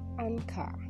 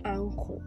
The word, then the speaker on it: anchor: And our word for today is anchor, and the correct pronunciation of the word is anchor, not ankle.